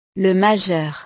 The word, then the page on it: le majeur: frances